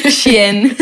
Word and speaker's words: Shein: Y llega esa segunda colección de Shein. – Sí, ¿lo dije bien? – Sí, Shein.